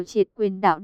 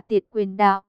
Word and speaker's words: tiệt quyền đạo: Tên của môn võ này chữ Hán là, nếu dịch theo đúng nghĩa Hán Việt thì phải đọc là tiệt quyền đạo.